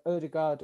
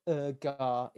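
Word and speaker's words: Ødegaard: Odegaard or Urgar, if you want to call him by his actual pronunciation and be a let it down.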